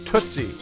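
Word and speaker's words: Tutzy: This record in the background is called Tootsie. At least I think that's how it's supposed to be pronounced. It's T-U-T-Z-Y by the Wayne Powell Octet.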